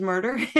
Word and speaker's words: Myrter: So you can reach out to me on LinkedIn, Facebook, Instagram, and Michelle Lee Myrter. Yes, my last name is Myrter.